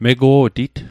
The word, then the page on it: mego'tit: Mi'gmaq